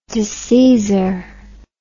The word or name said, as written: disseizor